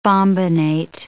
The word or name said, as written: bombinate